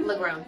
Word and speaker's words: Legrone: Legrone, yeah. – Legrone, mm-hmm. Just like it's spelled.